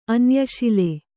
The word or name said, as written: ಅನ್ಯಶಿಲೆ